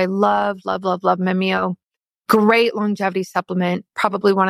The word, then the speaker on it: Mimio: So Mimio is spelled M-I-M-I-O, Mimio.